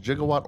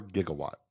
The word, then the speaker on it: gigawatt: Yeah, it's pronounced gigawatt or gigawatt. Either way. – So it's a G.